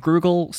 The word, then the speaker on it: Grugle: It's like the last name Grugel, spelled G-R-U-G-L-E.